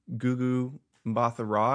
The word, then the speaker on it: Gugu Mbatha-Raw: Gugu Mbatha-Raw, I believe is how you pronounce it, as Renslayer, like Owen Wilson's Mobius's, like, boss, but she's also his friend, you know what I mean?